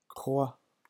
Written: croix

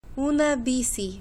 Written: una bici

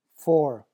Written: four

